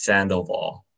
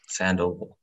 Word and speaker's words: Sandoval: Sand and then oval. Yeah, that's the way I say it when people need to spell it out. I split it into two. Sand okay. – And then oval. Sandoval.